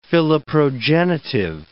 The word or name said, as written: philoprogenitive